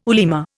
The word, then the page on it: ulema: Indian